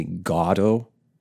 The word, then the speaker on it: Godot: And Beckett himself never really settled, probably in his own mind, on the way to pronounce Godot. Waiting for G-O-D-O-T. Some say Godot, some say Godot. You can pronounce it in many different ways.